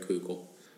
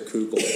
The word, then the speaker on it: Koukl: One is called Tactics by Greg Kugel. – Can you spell that? – It is Tactics or Kugel.